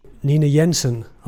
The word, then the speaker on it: Nina Jensen: Nina Jensen.